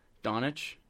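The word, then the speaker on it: Dončić: Not once have you said his name correctly. – Luka Doncic? – Doncic. Doncic. Like, you just... It kills me each time.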